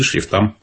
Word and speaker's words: шрифтам: Ограничения есть и по шрифтам. Именно так ставится ударение, правильно? Шрифты, шрифтам. Их тоже должно быть не более трех.